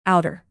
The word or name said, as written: outer